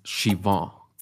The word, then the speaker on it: Siobhan: Eh, ella es Shivo Guerrero, ya me regañó porque no, yo según yo pronunciaba su nombre excelente y no, la verdad es que es Shivo como, con, como nasal, como chivo Guerrero. Eh, bueno, Chivó es este, bióloga, es filósofa, em, es una intelectual eh, de la UNAM.